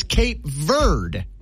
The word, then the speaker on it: Cape Verde: But the Cambridge Dictionary says it's Cape Verde.